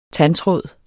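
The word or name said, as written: TANDTRÅD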